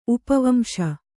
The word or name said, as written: ಉಪವಂಶ